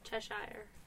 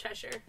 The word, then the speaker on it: Cheshire: Cheshire. – But I just said it wrong, but it's Cheshire.